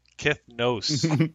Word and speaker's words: Kithnos: Kithnos, right? Kith, like with an E kind of? – He actually spelt it on his character name now. – Kithnos. – Kithnos. – That's like phonetically how you say it.